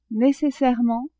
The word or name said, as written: necessairement